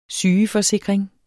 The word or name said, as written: sygeforsikring